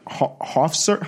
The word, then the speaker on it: Hofstra: He went to college at like Hofstra.